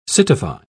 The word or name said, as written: citify